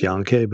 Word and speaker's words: Janke: I think it's pronounced Janke, but it's J-A-N-K-E, called The Healing Promise of Qi.